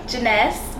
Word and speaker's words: Jhenesse: Jeunesse. J-H-E-N-E-S-S-E. Rhymes with finesse.